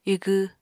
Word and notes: igw: apatani

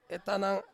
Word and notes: tanang: Ansus

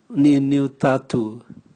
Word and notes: niuniu tatu: Raga

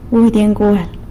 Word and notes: budy ngual: sanlucasquiavini

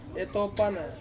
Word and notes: topana: Ansus

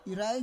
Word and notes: irai: Roon

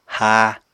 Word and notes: háa: apatani

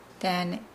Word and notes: daane: Nukuoro

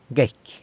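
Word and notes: guecy: San Lucas Quiaviní Zapotec

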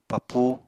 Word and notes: papú: Apatani

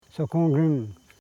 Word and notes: sokongim: so.kon.gim

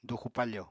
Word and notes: duxu-pafo: apatani

